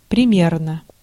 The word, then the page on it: примерно: [prʲɪmʲernə]